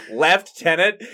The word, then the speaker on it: lieutenant: I'm like, okay, then where's the F in lieutenant? – Oh. – Okay, like, left tenant.